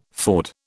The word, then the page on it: ford: British English